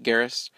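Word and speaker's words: Geras: Another part of that leak was that the newest character, there is a new character named Garrus, spelled G-E-R-A-S. I'm just going to say this is pronounced Garrus.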